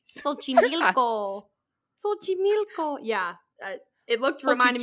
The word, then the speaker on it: Xochimilco: The Island of Dolls, or La Isla de Muñecas, is located in a borough of Mexico City called, I had to write this out phonetically, Xochimilco. Boop, boop, boop, boop. It's called Xochimilco, but it's spelled with an X in the front.